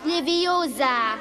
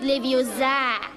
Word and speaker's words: Leviosa: Und übrigens hast du die falsche Betonung. Es heißt Leviosa und nicht Leviosa.